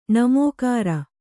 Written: ಣಮೋಕಾರ

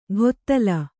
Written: ಘೊತ್ತಲ

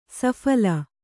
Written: ಸಫಲ

